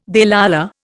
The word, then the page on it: Delilah: Indian